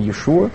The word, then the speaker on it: Иешуа: Правильное ударение Иешуа. То есть Иисус – это уже какие-то греческие всякие варианты.